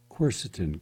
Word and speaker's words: quercetin: Quercetin, Q-U-E-R-C-E-T-I-N, quercetin, three syllables, easy.